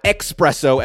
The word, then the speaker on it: espresso: Not espresso, as Americans say. It's espresso with an S, no X.